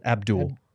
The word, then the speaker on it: Abdel: Abdul. – It is Abdul. – It's spelled Abdel, but it's pronounced Abdul.